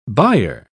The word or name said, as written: buyer